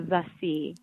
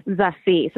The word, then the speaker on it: Zassi: Zassi. So the second Z sounds like an S, and there's an inflection on the C. Zassi. – Zassi. Like that? – Yeah, Zassi.